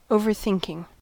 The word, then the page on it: overthinking: American English